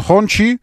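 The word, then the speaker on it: Hongqi: Правильно говорить не Хочи, а Хачи, если вы в множественном числе говорите. Вот, а если вы про автомобиль, тогда он Хончи, и это ФАВ.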